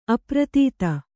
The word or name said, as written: ಅಪ್ರತೀತ